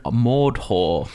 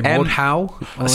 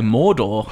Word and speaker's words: Mordhau: I keep getting to say Mordhau. – So did I. I keep just saying Mordor. Well, that's completely wrong. It's not a Lord of the Rings game.